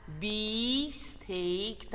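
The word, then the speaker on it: beefsteak: Это английское слово. Произносится оно так. Бифстейк, там дифтонг. И долгое.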